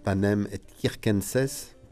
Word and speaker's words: panem et circenses: Vous souvenez de la devise romaine, hein, panem et circenses, bien prononcée selon la prononciation dite restituée, donc qui veut dire du pain et des jeux.